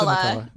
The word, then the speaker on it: McCulloch: it's I think he pronounced McCullough.